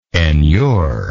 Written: enure

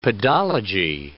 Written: pedology

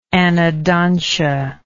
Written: anodontia

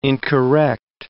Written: incorrect

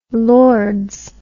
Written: lords

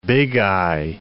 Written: bigeye

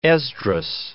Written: Esdras

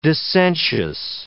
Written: dissentious

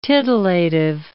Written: titillative

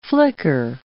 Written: flicker

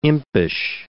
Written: impish

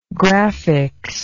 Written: graphics

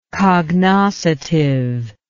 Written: cognoscitively

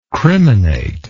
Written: criminator

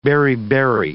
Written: beriberi

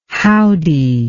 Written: howdie

